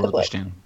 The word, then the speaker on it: Balochistan: Yeah, Balochistan, is that how you pronounce it? – Yeah, that's Balochistan.